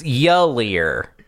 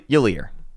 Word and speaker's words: Yulier: It's Yulier. I tend to write it as Yulier when spelling it phonetically. Because the first syllable is very short and quick, but I think more people will get it right if I spell it out as Yulier.